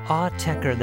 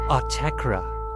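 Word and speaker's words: Autechre: Autechra, the master says, not Autechra.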